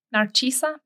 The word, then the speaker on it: Narcisa: Narcisa Pricope.